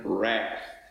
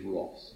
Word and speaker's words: wrath: If you prefer the American, wrath. But the correct pronunciation is wrath.